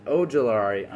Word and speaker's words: Ojulari: Olari at number four. Did I pronounce it wrong? – Ojulari. – I'm sorry. B.J. Ojulari at number 41, just because it looked like he could be in the first round.